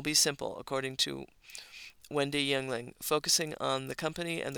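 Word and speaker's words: Yuengling: The correct pronunciation is Yengling.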